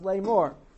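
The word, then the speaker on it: Lamor (לאמר): So Lamor can be written with a vav or without a vav. And either way, it's pronounced Lamor.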